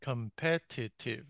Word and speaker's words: competitive: Competitive salary same Competitive, it's four syllables.